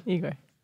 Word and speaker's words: Igor: Igor.